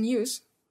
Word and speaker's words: Hughes: Und habe mir jetzt Blutrivalen von Declan Hux, oder wie auch immer der ausgesprochen wird, ähm, bestellt. – Hughes, würde ich mal sagen. – Declan, Declan Hughes. Declan Hughes. Sagst du?